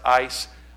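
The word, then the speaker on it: eis (εἰς): I think it's A-C-E, but it's pronounced eis.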